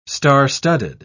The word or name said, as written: star-studded